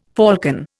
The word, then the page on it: falcon: Indian